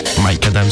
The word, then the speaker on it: Micadam: Mike Adam, c'est comme ça que vous prononcez Mike Adam, si si.